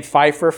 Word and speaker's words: Pfeiffer: Well, you know how sometimes the word P can be pronounced like an F? Like the name Pfeiffer, for example, P-F-E. So I said faith with a P, though, P-F-A-I-T-H.